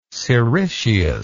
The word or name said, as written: siricius